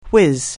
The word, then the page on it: whizz: anglais